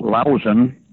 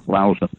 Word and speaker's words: Lahausen: It's pronounced Lausen, but it looks like Lahausen, L-A-H-A-U-S-E-N. – Oh. – Lausen.